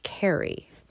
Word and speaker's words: Carew: spelled C-A-R-E-W. And I actually had to re-record the poem because it's pronounced Carey, Thomas Carey, who was born in 1595 and lived to 1640.